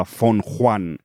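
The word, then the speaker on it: Fenghuang: Se escribe Feng Huan, lo veréis en la de esta, pero estoy viendo que se pronuncia algo parecido a Feng Huan, que es como trataré de pronunciarlo durante todo este podcast.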